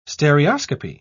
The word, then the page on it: stereoscopy: British English